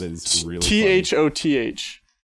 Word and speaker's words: Thoth: It's Thoth. it's that's how you say it. Thoth.